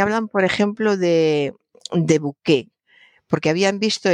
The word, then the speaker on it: buqué: hablaban del bouquet de un vino, como suena, B-U-Q-E con tilde, el bouquet de un vino. Es perfectamente correcto, es la adaptación del francés, bouquet, solo que en francés se escribe bouquet, se ha adaptado al castellano como bouquet.